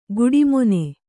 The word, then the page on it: ಗುಡಿಮೊನೆ: guḍi mone